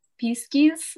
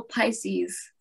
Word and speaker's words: Pisces: I found it in a computer game and I thought, what's a piskies? How do you say this? And it was I asked my parents and they said, oh, Pisces.